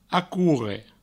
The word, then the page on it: acorre: acourre